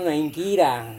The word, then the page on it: ingkira: Serewen